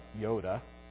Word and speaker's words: Joda: son of Joda. Actually, Hebrew pronunciation better there would be son of Yoda.